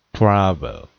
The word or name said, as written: travel